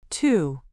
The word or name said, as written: to